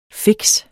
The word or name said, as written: fikse